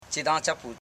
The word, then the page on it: chidanchapu: chi.dan.cha.pu